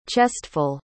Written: chestful

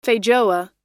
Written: feijoa

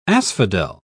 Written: asphodel

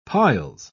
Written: piles

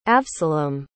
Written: avsalom

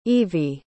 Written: Evie

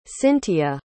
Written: cyntia

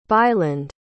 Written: byland

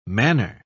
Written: manner

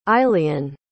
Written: ailean

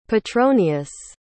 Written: petronius

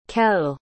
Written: kell

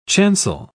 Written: chancel

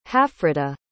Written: halfrida

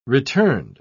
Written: returned

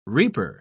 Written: reaper

